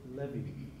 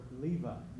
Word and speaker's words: Levi: That's how the Hebrews would pronounce it, Levi or Levi.